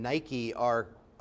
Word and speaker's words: Nicaea: Nicaea and Nike are etymologically connected because it means victory in Greek. So Nicaea is a town named after victory, and it would be in Greek pronounced Nikea, and the lake that's there is Iznik.